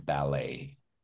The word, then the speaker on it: ballet: Remember, it's the T is silent, but nobody made the mistake, but many people say ballet, but it's ballet. Okay, the French way.